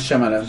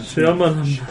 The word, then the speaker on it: Shyamalan: Night Shyamalan. Si es que así se pronuncia. – Sí, M. Night Shyamalan. – Shyamalan.